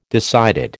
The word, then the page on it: decided: American English